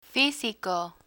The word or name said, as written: fisico